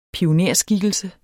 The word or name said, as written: pionerskikkelse